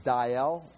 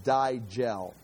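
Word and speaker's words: Dajjal: And I found it pronounced two different ways. One is I've got it in here. I've tried the book, I have it pronounced Diel, just Diel. The J's are silent. I also found an audio recording online that says Digel, Digel, Digel.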